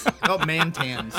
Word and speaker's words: MTNS: Is that how you pronounce it? – God bless you. – Well, it's mantans.